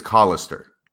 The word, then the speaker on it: Callister: And actually, it's Callister. – Callister. Look at that. – Yeah. So don't be mispronouncing his name.